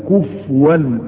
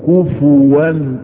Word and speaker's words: كفوا: اصل الكلمة كما قال العلماء كفءا احد، وارجو ان ننطقها نطقا صحيحا، فكثير من المسلمين يسيئون نطقها، يقول لك ولم يكن له كفوا، نسكنها وهذا خطأ، وانما تضم ولم يكن له كفوا احد.